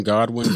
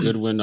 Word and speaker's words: Godwin: John Godwin, Goodwin, I've heard it pronounced both ways.